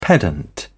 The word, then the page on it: pedant: British English